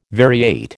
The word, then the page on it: variate: American English